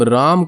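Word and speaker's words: Ram: राम cult यानी कि रैम cult आप उसको कैसे भी बोलो लेकिन स्पेलिंग तो वही है आर ए एम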